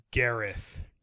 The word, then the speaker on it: Gareth: Is it Gareth or Garth? – It's Gareth.